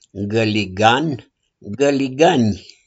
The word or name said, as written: GĂLIGAN